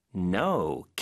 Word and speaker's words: know: No, K-N-O-W. – 그렇죠. K-N-O-W. No로 발음되지만 K는 묵음이죠.